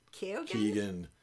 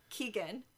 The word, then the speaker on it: Keoghan: Well, I went with, and I'm going to mispronounce the name, Barry Keoghan? – Keegan. – Keegan.